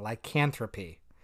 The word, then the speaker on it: lycanthropy: Lycanthropy. There we go. That is the actual pronunciation. Lycanthropy.